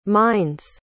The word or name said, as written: mind's